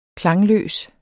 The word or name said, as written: KLANGE